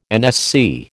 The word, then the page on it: NSC: American English